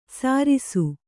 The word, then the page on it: ಸರಿಸು: Kannada